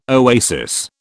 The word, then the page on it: oasis: British English